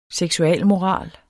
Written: seksualmoral

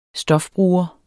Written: stofbruger

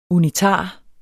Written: unitar

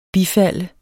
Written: bifald

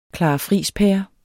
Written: clarafrijspære

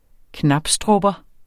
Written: knabstrupper

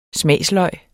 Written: smagsløg

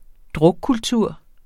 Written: drukkultur

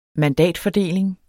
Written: mandatfordeling

